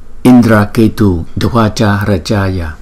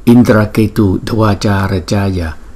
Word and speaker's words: Indraketu Dvaja Rajaya: Hồng Diệm Đế Tràng Vương Phật, chúng ta sẽ phát âm là Indraketu Dvaja Rajaya, Indraketu Dvaja Rajaya, Indraketu Dvaja Rajaya.